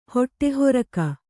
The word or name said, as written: ಹೊಟ್ಟೆಹೊರಕ